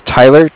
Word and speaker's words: Tyler: My name is Tyler, T-Y-L-E-R. – Yep. – And I'm calling with Cardiff. It's spelled C-A-R-D-I-F-F, as in Frank, Frank.